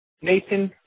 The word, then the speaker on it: Nathan: It's Sergeant Nathan Fredericks. Let me spell that for you. That's N-A-T-H-A-N. Last name is F-R-E-D-E-R-I-C-K-S.